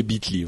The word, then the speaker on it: Bitly: Euh, je sais pas comment tu le prononces, mais oui, oui, c'est Bitly, ouais.